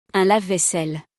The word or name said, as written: Un lave-vaisselle